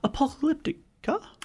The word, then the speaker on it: Apocalyptica: That was See Through Bedsheets, and before that, KDA the Baddest and Apocalyptica. – Apocalyptica.